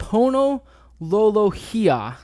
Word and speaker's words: Lolohea: So while we're on the subject of the WSU defensive line, let's talk about Pono Lolohia. – Yeah. – I'm correct. That's correct pronunciation.